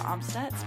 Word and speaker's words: Amstutz: Well, you can find me on all social platforms at Sandra Amstutz.